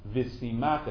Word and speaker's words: V'simach: It says the first year he's supposed to be with his wife, excuse me, I pronounced the pasuk incorrectly, V'simach es ishto, asher lakach. In fact, the way I read, the way I vowelized the pasuk is exactly what Rashi tells us not to do.